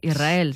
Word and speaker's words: Israel: Y la S con la R es difícil de pronunciar. La gente dice Israel, es verdad. – Que suene la S. Israel, sí, es difícil de pronunciar.